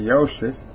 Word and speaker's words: Joseph: And his brethren, James and Joseph and Simon and Judas, actually that Joseph pronounced with a J is supposed to be with a Y, Joseph.